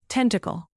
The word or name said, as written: tentacle